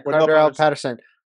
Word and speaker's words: Cordarrelle Patterson: Cordell Patterson. – Yeah, Cordarrelle Patterson.